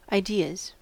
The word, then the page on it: ideas: American English